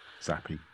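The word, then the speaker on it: Zappe: Zappi.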